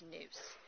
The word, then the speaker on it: nous: The Greek word for mind is nous, which is pronounced nous.